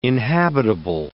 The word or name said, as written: inhabitable